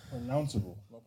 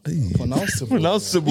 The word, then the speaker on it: pronounceable: But this one is pronunciable. Pronunciable. – From listening to this, yeah. – No, wait. Try pronounce it. – Pronunciable, I think. – Pronounceable. – Pronounceable.